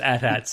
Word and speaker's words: AT-AT: I from George Lucas's mouth, it's pronounced AT-AT, so I'm going with it.